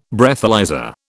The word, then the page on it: Breathalyser: British English